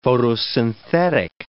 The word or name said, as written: photosynthetic